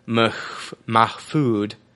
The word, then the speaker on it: Mahfood: Jim Mahfood. Wow, it sounds like he's actually – his last name is spelled M-A-H-F-O-O-D. It sounds like he's talking about, like, his food.